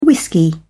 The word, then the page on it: whisky: British English